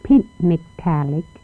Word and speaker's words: Pitmegtalik: And it is spelled Pitmegtalik, sounds Pitmegtalik Village, and it is Pitmegtalik, Pitmegtalik.